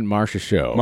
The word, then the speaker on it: Marchessault: Jonathan Marchessault. – Marchessault? – From Vegas.